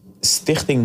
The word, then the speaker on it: Stichting: But I want to give the money to Stichting Mai. I don't know if that's the English word though, but that's how it's pronounced here in the Netherlands.